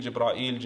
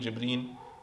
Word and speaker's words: Jibreel: And by the way, Jibreel has 6 different pronunciations. Jibreel, Jibra'il, Jibreen. There's different ways that it's pronounced.